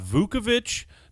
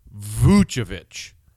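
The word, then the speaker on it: Vučević: Not Vukovic, not Vucevic, Vucevic. And it's Nikola, emphasis on the knee, first name.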